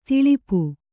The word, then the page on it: ಥಳಪು: kannada